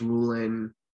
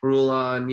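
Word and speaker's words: Ruhlin: Corbett, when he was around still, Fitzsimmons, Gus Rulin, pronounce his last name correctly? – Something like that, yeah. – Yeah, Rulon,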